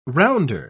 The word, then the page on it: rounder: British English